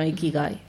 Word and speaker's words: ikigai: Bueno, Ikigai, sí. Ikigai. Ikigai es como, bueno, lo que te empuja a la vida, realmente. O sea, tu motivo de existencia. Uh-huh. Es lo que es Ikigai, literalmente.